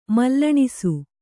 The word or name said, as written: ಮಲ್ಲಣಿಸು